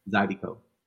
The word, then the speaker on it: Zydeco: Exotico dance at it. I pronounced it right. Exotico? – Zydeco. Yeah. – Zydeco. – Mm-hmm. Gotcha. Z-Y-D-E-C-O.